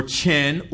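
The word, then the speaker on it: hen (חן): First of all, there's the word hen, H-E-N, H-E-N. The first Hebrew word for grace is hen. H-E-N. It's pronounced hen or chen or even cane.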